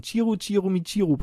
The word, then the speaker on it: Chiru Chiru Michiru: Jetzt habe ich es Englisch ausgesprochen. Chiru Chiru Michiru Part 2, das ist richtig japanisch ausgesprochen.